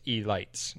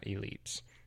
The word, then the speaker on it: E-lights: It's spelled like elite, but with a dash between the E and the L. So it's E-lights, not elites.